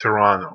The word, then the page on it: Toronto: local